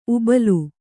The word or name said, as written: ಊಬಲು